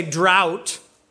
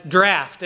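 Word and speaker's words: draught: I've read too many British novelists, including Lewis and Tolkien, not to come across the word draft, but spelled D-R-A-U-H-T. I want to say drought, but every pronunciation guide says draft. In English, we say draft, D-R-A-F-T, D-R-A-U-G-H-T, draft. It's the British, it's the fancy way of saying it.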